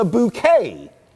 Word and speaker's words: Bucket: But you come to find out in the show, you know, she pronounces her name like it's spelled like a bouquet of flowers, B-O-U-Q-U-E-T, bouquet. But when she has to spell her name, she actually spells it B-U-C-K-E-T.